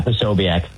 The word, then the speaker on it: Posobiec: And the Jack, how the hell do you pronounce that guy's name? Probst? – Oh, so How do you pronounce it? – Yeah, Posobiec.